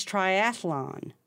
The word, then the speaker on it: triathlon: Yeah, you're putting, I believe that you're committing the mistake. It's triathlon. – Yeah, I am committing the mistake. – Yeah. There otherwise, triathlon. – It doesn't have another A. – I'm throwing in that extra syllable.